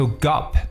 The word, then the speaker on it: gulp: 它这边有一个舌侧音 l 的发音，gulp。 So gulp means to swallow large amounts of food or drink quickly，就是我们所说的这种大口的吞咽或者匆匆的吞下的一层意思。